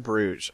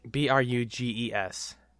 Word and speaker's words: Bruges: That's where the city of Bruges is. – Oh, that kind of Bruges. Okay. – Bruges. – I was thinking like Brug, which is like a kind of champagne.